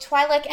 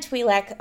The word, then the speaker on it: Twi'lek: Both Twi'lek and Twi'lek are correct. Cool. The pronunciation is based off of what side of Ryloth you were from. – Oh. – Yeah. – Fascinating. – So Twi'leks pronounce their own names differently depending on where they're from. – Yeah. So it's literally dialect based. – It's dialect based.